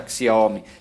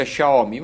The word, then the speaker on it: Xiaomi: Abbiamo parlato spesso e volentieri di Xiaomi, nostri amici che lo utilizzano, che sanno un po' di cinese, ci hanno detto no, non si pronuncia Xiaomi, si pronuncia Xiaomi, va bene, Xiaomi.